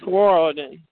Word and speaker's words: Riordan: Rick Riordan.